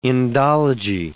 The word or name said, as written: Indology